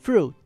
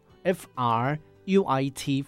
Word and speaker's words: fruit: 好，所以泛指所有的哈这个果类水果哈都可以，不只有果类啦，瓜果啦，李子啦，哈，这只要全部的水果就叫做 fruit。听发音会很像 food 哦，好，不是哦，fruit， F R U I T， fruit。